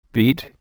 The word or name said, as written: beet